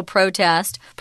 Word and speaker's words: protest: Pronounced the same way, the emphasis doesn't change. It's still protest.